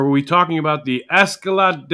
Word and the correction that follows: Escalera: de la Muerte? – Escalera. Escalera. De Did I say it wrong in the NXT minute?